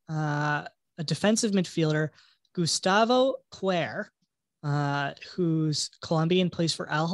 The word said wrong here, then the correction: Cuellar: I said that wrong, didn't I? – Cuellar. – Okay. – Cuellar.